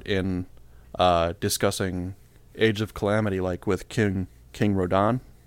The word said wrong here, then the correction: Rohan: Is that how his name? Is that how it's pronounced? – Rohan.